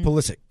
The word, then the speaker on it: Pulisic: Saw that one goal scored by, and I cannot pronounce his name, Christian Pulisic.